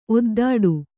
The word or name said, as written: ಒದ್ದೆಡೆ